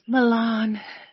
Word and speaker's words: Milan: You have to say it like this, Milan.